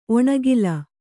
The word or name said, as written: ಒಣಕಲು